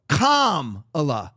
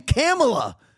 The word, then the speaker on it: Kamala: So he says, Don Lemon says that her name is to be pronounced Kamala, like comma.